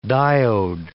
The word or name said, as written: diode